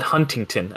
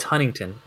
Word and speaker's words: Huntington: People call it Huntington. And I'm like, no, it's Huntington. People pronounce that T.